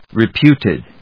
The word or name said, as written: reputed